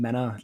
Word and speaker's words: Mena: So, you can find me on LinkedIn, Benjamin Mena, last name is spelled M-E-N-A, in the DC Metro.